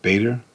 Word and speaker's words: Bader: And Ruth Gader Ginsburg, is it? I think I pronounced it right. Ruth Bader Ginsburg.